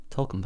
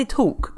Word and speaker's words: talc: Talcum powder. – Talcum powder. – How do you pronounce this? – Talcum powder. – Talc. – Talc. You don't say talk.